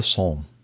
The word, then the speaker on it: Somme: Which in this case is the Battle Above the Somme.